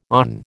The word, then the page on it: awn: American English